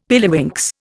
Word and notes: pilliwinks: Indian

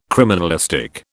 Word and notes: criminalistic: British English

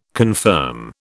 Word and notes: confirm: British English

